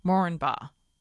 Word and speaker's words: Moranbah: I said Moranbah, and he said the accepted way to pronounce the name is Moranbah. The first A is not stressed.